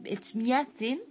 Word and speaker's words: Echmiadzin: Es Echmiadzin, creo que se dice así,